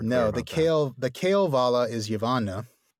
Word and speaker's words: Kalevala: So the emphasis is on the first. – Yeah. I think I've always said Kalevala in the past. – Yeah. I had too. – And I started hearing Kalevala. – Okay. – Which is very subtle. I think it's just, is it the first syllable or the third syllable that is more pronounced, but right.